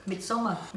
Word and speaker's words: Midsommar: How do you pronounce it? – Midsommar, yeah.